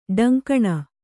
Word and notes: ಡೊಂಕಣ: ḍoŋkaṇa

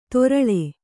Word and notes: ತೊರಳೆ: Kannada

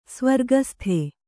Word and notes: ಸ್ವರ್ಗಸ್ಥೆ: svargasthe

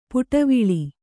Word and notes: ಪುಟವಿಳಿ: puṭaviḷi

